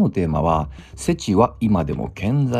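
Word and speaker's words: SETI: まあ、それの英語の頭文字を取って、世知と読みます。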